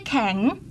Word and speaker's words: แข็ง: อาหารแช่แข็งเรียนแล้วนะคะสระแอะเมื่อมีตัวสะกดสระอะจะเปลี่ยนรูปเป็นไม้ไต่คู้ดังนั้นคำนี้จึงอ่านว่าขอแอะงอแข็ง